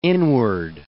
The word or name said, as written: inward